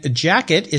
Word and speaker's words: Jaqet: And I'll remind our listeners again, Jacket is spelled J-A-Q-E-T, right? – Correct.